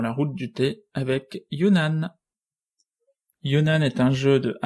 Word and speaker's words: Yunnan: Alors, prononciation officielle. – Attention, je te laisse faire. – C'est le Yunnan. – Le Yunnan.